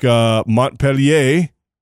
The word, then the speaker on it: Montpellier: You know, have them contact, you know, like Montpellier. By the way, that is how you say it.